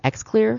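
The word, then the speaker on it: Xlear: Now you can also, it's called X-Clear. Mm-hmm. I think I'm pronouncing it correctly. It's X-L-E-A-R.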